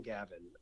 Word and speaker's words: Gaven: Gaven and my name is spelled J I M G A V E N for anybody listening.